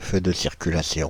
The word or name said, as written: feu de circulation